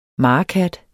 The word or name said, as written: marekat